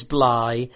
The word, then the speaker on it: Blythe: It's Dave Blythe. That's what your name is. Although I did hear that apparently it is Bly. That is the correct pronunciation.